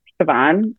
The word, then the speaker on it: Chevonne: So she named me after her best friend, Chevonne, which is spelled C-H-E-V-O-N-N-E.